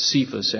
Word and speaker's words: Cephas: The word there for stone is cephos, and it simply means a pebble. I think it's cephas, actually, the way you pronounce it.